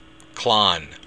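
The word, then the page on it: clann: Irish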